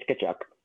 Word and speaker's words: Tkachuk: First, at the end, Matthew Tkachuk, I believe I'm pronouncing his last name right. I don't want to be Don Cherry. – I believe it's because I know his nickname or one of his nicknames is Chucky, right? Or yeah, Chuck. You're right. It's Tkachuk.